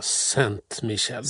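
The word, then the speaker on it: Ste. Michelle: Ska vi uttala det här? Chateau Saint-Michel eller vad säger man? – Saint-Michel. Saint-Michel till och med.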